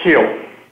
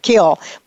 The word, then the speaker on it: Kiel: Michael, is it pronounced Kiel or Kyle? – It's pronounced Kiel. – Kiel.